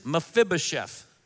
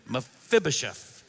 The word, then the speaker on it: Mephibosheth: His name is Mephibosheth. Say that with me, Mephibosheth.